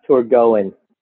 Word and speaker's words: Torgoen: Uh, and you're right, we do get a bunch of different uh, uh, responses to the name, but the official uh, name is pronounced Torgoen.